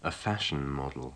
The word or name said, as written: a fashion model